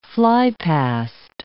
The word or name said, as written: flypast